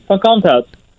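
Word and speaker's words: van Kalmthout: VeeKay is essentially a shortened version of your surname, which is, and I don't want to mispronounce this as a disrespect to your family, Von Comtaut, is that right? Did I say it correctly? – Pretty close, yeah, Von Comtaut.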